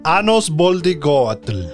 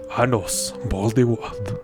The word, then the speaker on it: Anos Voldigord: Que es Misha, Necron y Anus Boldigord. Que pues... No es Anus. Dilo como es porque así lo pronuncia él. Anos Voldigord. Anos Voldigord.